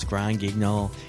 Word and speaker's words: Grand Guignol: Eventually, I'll be able to pronounce Grand Guignol properly. I figured it within a year. – Well, that was a pretty good job there.